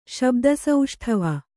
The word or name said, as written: ಶಬ್ದಸೌಷ್ಠವ